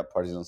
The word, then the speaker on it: Partizan: At the, I might butcher this, the Partizan Stadium? – Yeah, Partizan Stadium.